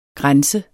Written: grænse

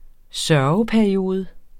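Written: sørgeperiode

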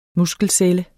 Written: muskelcelle

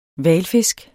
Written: hvalfisk

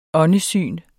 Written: åndesyn

